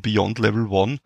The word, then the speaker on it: Beyond Level One: Dann darf ich bei mir heute begrüßen einen Teil der Beyond Level One, wenn ich das richtig ausgesprochen habe? – Ja, einen, eigentlich den Teil von Beyond Level One, weil Beyond Level One besteht aus dem Philipp Herberstein, den Jan Lederer und mir.